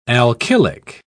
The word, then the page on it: alkylic: British English